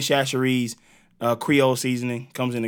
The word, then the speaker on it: Chachere's: Tony Chachere's. – So Tony Chachere's.